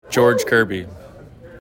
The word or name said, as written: George Kirby